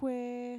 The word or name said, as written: kuee